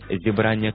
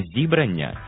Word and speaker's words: зібрання: Це сукупність творів одного автора, наприклад, зібрання творів Тараса Шевченка, або друге значення – зустріч групи людей, наприклад, церковне зібрання. І увага, прошу запам'ятати правильний наголос. Не зібрання і не зібрання, як можна часто почути, а саме зібрання. Наголос на другому складі.